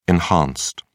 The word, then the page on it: enhanced: British English